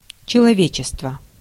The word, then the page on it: человечество: /t͡ɕɪlɐˈvʲet͡ɕɪstvə/